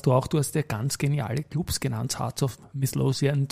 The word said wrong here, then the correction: Heart of Midlothian: zum Beispiel, wenn ich es jetzt halbwegs richtig ausgesprochen habe, eine der Top 5 Mannschaften in Schottland. – Heart of Midlothian.